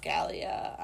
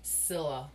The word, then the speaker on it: Scylla: Scalia. I don't know. – Scylla.